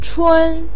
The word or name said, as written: chun1